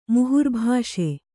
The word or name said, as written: ಮುಹುರ್ಭಾಷೆ